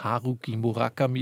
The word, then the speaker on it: Haruki Murakami: Die jetzt von unserer großen ARD-Aussprache, Datenbank empfohlene Aussprache ist Haruki Murakami.